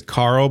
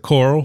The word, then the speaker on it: Carl: His name is Carl, but he always pronounced it Coral.